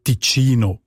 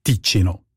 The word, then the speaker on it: Ticino: Und im Italienischen ist es ja auch Ticino und nicht Ticino.